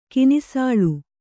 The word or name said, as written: ಕಿನಿಸಾಳು